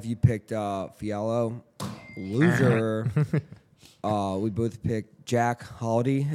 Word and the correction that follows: Hadley: Is that how you say it? – Jack Hadley. – Hadley, winner.